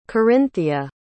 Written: Corinthia

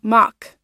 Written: mach

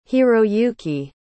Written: hiroyuki

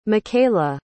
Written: michaela

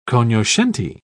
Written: cognoscenti

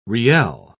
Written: riel

